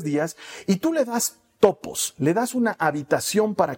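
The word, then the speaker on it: topos (τόπος): Ahora, esta palabra, esta frase da lugar, viene de una palabra griega que se pronuncia topos y que literalmente significa darle espacio darle cabida, o más literalmente todavía, darle una habitación.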